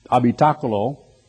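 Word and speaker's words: habitáculo: But really the word habitation, which has a dual meaning in Greek as well as in the Latin, habitaculo is the way it's pronounced in Spanish, and it means responsibility as well as a place where you live.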